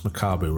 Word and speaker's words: Makabu: Now, did I pronounce Makabu right? – You did very well.